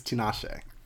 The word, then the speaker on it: Tinashe: Tanisha ain't got it. – You know, we'll stop calling her Tanisha. – Her name, that's how you pronounce it, right? – No, I think it's Tinashe.